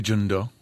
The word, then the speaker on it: Jeong-do: What's fascinating to me is that when the character Jeong-do, is that how you say it? – Sure. – Or you say it for me. – I say Jeong-do. – Do, yeah. Okay. Do, like Play-Doh.